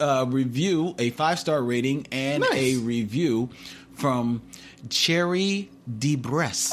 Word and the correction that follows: Cherie DeBress: Oh, Cherie DeBress. That's my cousin. – Oh, Cherie DeBress. Yeah. I said it incorrectly.